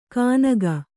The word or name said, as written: ಕಾನಗ